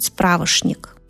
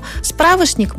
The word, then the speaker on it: справочник: Нам странен человек, который в нынешнем мире произносит «справошник». Но мы помним, что была такая старая норма, что было принято произносить «ше» в некоторых словах.